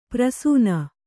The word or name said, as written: ಪ್ರಸೂನ